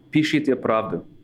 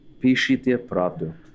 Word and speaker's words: Pishite pravdu: Pishite pravdu. – Pishite pravdu? – Pishite pravdu.